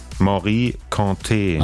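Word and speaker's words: Mory Kanté: Wie wird's ausgesprochen? – Morikante. – Ach so, guck mal. Das war also falsch.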